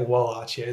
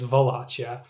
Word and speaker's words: Wallachia: The funny thing about Wallachia is that, and I keep saying it Wallachia, it's Wallachia.